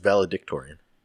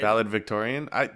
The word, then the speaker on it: valedictorian: All right, homeschool, but it's not Victorian. I definitely have always pronounced it that way. – No, it's valedictorian.